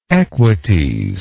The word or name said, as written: equites